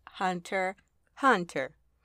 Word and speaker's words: Hunter x Hunter: No, actually, um, that's what it says on the paper or written down, but I've been told over the years that it is pronounced Hunter Hunter.